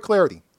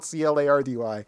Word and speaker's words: Clardy: at Troy Clardy. Last name is spelled C L A R D Y at Troy Clardy.